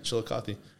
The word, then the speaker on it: Chillicothe: Chillicothe. How do you say it? – Yeah, Chillicothe. It's near.